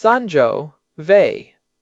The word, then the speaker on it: Sangiovese: Fourth term, and this is a specific grape variety, Sangiovese, pronounced Sangiovese.